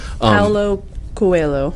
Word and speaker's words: Paulo Coelho: Paulo Coelho.